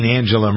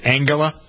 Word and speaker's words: Angela: Looking over the list at 15, Angela Merkel. Are you supposed to pronounce it Angela? It's Angela.